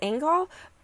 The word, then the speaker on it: Angyal: In Turning Point, journalist Chloe, I think her last name's pronounced Angyal, but it's A-N-G-Y-A-L, just in case.